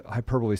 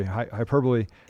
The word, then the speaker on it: hyperbole: We got another hyperbole. Sorry, hyperbole. – If this show accomplishes nothing, I'm going to get you to pronounce hyperbole correctly.